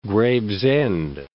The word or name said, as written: Gravesend